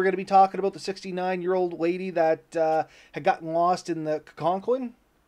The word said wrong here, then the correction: Coquitlam: Am I saying that right? Do you know, Ben? – Is it Coquitlam, I think it was.